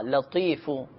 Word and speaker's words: لطیفو: آپ کو چاہیے کہ آپ یا لطیفو یا لطیفو یا لطیفو، لام تو یا فا لطیفو، اس میں تو ہے، بعض اوقات صحیح تلفظ ان کے نہیں ہو پاتے تو وہ لطیفو کو لطیفو پڑھ لیتے ہیں، یعنی تو کو تا میں بدل دیتے ہیں، تو صحیح تلفظ ہے یا لطیفو.